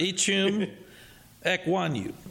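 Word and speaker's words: Ickey Ekwonu: And I'll try my TV pronunciation here. Ichum? – I believe that's correct. – Ichum Ekwonu. – There you go.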